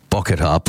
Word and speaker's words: Bocchetta: I'll spell it for you. It's BOCCHETTA. Bokatar. BOCCHETTA. Bokatar plush toys.